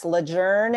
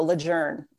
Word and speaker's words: Lejeune: Yes. Lejeune is the way that wow. it needs to be pronounced. And there's a whole long backstory about how it's Lejeune is how folks have been saying it, but that's actually incorrect. So Lejeune, you heard it here first. Lejeune.